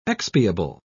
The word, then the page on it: expiable: British English